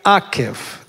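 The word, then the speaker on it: акев (עקב): Ударение на первую букву «акев». Давайте все вместе. «Акев».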